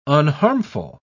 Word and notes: unharmful: British English

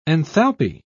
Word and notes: enthalpy: British English

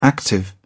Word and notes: active: British English

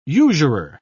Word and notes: usurer: British English

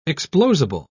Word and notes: explosible: British English

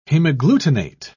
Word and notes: hemagglutinate: British English

